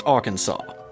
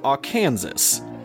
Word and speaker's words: Arkansas: So, as far as I know, it's pronounced Arkansas. But I know that there are some places in the South that really do pronounce it Arkansas.